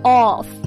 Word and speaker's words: off: 这个 knock off 指的就是啊，提前下班，要注意一下 off 的发音，对吧？长元音 off。